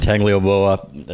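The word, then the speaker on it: Tagovailoa: Yeah, with a quarterback and Tagliavoa, if I hopefully pronounced that right. – He did a good job.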